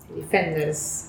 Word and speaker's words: Defenders: Defenders, ¿verdad? – Sí.